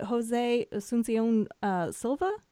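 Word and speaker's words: José Asunción Silva: Is it Jose Asuncion Silva? Is that how you pronounce it? – Silva, that's right.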